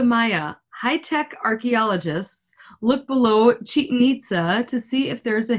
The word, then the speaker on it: Chichen Itza: I don't know if it's pronounced Chichen Itza, but it sounds good to me. – It is because I looked it up.